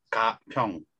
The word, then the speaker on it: Gapyeong: B-A-N-E. – Yes. – And just to correct myself, Gapyeong. Is that good? – Gapyeong.